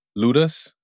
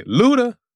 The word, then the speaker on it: ludus: ludus, which, I mean, I want to say luda, but it's definitely not pronounced that way,